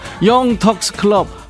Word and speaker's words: 영턱스 클럽: 영텅스 클럽 하얀 전쟁 신청한다. 야 로이야 너 진짜 재미나게 사는구나. 계속 그렇게 살아. 영텅스 클럽 왜 이렇게 발음이 안 되냐? 영턱스 클럽 하얀 전쟁 들어.